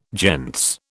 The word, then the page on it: gentes: British English